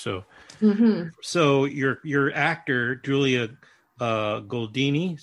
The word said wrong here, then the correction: Goldani Telles: is that how you say it? Tell us. Julia Goldani Telles.